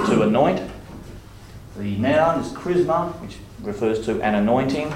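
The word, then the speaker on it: chrisma: And then the other word, be careful how you pronounce this, it is charisma. Not charisma, as in charismatic, because that word comes more from the word grace.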